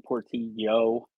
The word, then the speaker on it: Portillo: You can find me on Twitter, Anthony Portillo, like Y-O, because you gringos don't know how to say my name right.